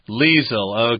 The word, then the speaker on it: Liesel: I would say that in German you probably say Liesel or something like Liesel. – That. Liesel, okay. But in any case here, I pronounce this Liesel. Sounds close to Lisa there.